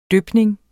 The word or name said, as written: dypning